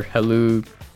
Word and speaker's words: Helou: You can look at me on LinkedIn under Alexander Helou Dekos. My last name is spelled H-E-L-O-U.